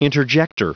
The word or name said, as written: interjector